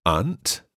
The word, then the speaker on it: aunt: Aunt se escribe A-U-N-T. – A-U-N-T. – También hay otra palabra en inglés que es aunt. Suena perfectamente lo mismo, pero se escribe A-N-T. – A-N-T.